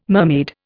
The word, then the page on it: mermaid: Indian